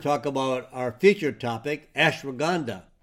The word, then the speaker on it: ashwagandha: Now ashwagandha is spelled A like an apple, S like Sam, H-W-A-G-A-N-D-H-A, ashwagandha.